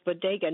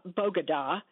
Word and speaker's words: bodega: And I don't speak Spanish, but I know that bodega is pronounced bodega, not bogada.